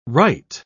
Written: wright

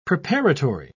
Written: preparatory

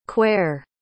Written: quare

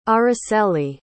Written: Aracely

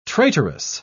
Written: traitorous

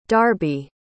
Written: darby

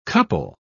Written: couple

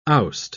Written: oust

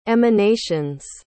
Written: emanations

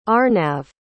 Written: Arnav

